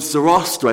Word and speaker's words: Zoroaster: I think that's the correct pronunciation. If you saw the spelling, it looks like Zoroaster. And I can't get away from roast in my head when I'm trying to pronounce this word.